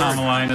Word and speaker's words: Commelina: He knows he said the wrong first letter once he heard the actual word. – Kamalina is a kind of plant. It's spelled C-O-M-M-E-L-I-N-A.